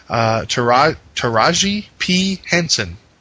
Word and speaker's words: Taraji P. Henson: Taraji P. Henson.